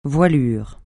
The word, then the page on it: voilure: francais